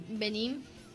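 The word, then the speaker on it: Benín: Benin. Vamos a buscar, Benin, porque no sé. – Con B larga y acento en la I.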